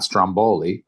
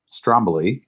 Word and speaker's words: Stromboli: And we had all the little islands in between Rome and Sicily, including Stromboli, not Stromboli, but Stromboli, as they pronounce it in Italy, which is actually an active volcano.